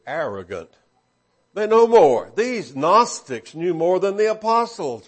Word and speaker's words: Gnostics: Now, we spell it in English G-N-O-S-T-I-C-S. If you were looking in the Greek and you were trying to spell it as we would say an English letter, it would have an I between the G and the N, ginosko, to know.